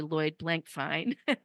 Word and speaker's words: Lloyd Blankfein: I've mispronounced his name, and I feel terrible about that, but he was the former CEO of Goldman Sachs, and I heard him speak in person, and his name is actually Lloyd Blankfein.